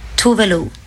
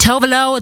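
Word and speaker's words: Tove Lo: It's Tuvalu. Voulez-vous Tuvalu? – Tuvalu. Nicht Tovalo, Toflo, wie viele auch sagen.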